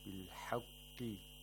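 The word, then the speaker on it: بِالْحَقِّ: Anzal al-kitaba bil-haqqi wal-mizan bil-haqqi. The qaf has to be pronounced with the lowest level of tafkhim. If you say what bil-haqqi, that's too much tafkhim.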